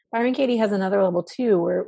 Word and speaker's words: Byron Katie: I think that's, I know Jill has, she introduced me to Byron Katie.